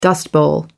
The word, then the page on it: dust bowl: American English